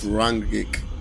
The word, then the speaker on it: Rangnick: Ralph Ragnick. – Ragnick. – No, I just think it's Ralph Rangnick.